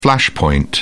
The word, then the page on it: flashpoint: British English